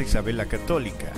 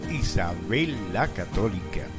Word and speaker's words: Isabel la Católica: Otro ejemplo, no es Plaza Isabel la Católica, es Plaza Isabel la Católica.